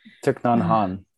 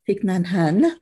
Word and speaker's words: Thich Nhat Hanh: Thich Nhat Hanh. – Thich Nhat Hanh.